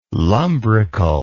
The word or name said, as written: lumbrical